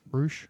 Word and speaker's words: Ruch: Yeah, Dan Roosh. – I think you're saying that right. – Yeah. – Roosh. – Roosh, R-U-C-H.